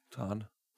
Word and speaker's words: Tarn: Tarn. – Tarn. – Tarn. – Tarn, tarning, yeah. – Spell it. – T-A-R-N. – T-A-R-N, tarn?